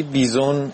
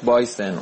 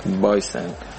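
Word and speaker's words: bison: یه پیغام دارم واسه فرید، تلفظا رو درست کن آقا فرید، اون چیزی که تلفظ کردی بیزون غلطه، اصلش از بایسن، اون بوفالویی که تعریف کردی تو جاده ای تو آلاسکا بوده و پلیس براش موزیک پخش کرده، تلفظ صحیحش هست بایسن.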